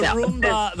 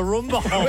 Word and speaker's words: Roomba: I have got one of those Roomba. I don't know how to pronounce it. Yeah, but the Roomba. The Roomba.